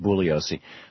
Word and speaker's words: Bugliosi: I mean, there was his book evokes so many questions, and that's certainly one that it would have been, and it's pronounced Bugliosi.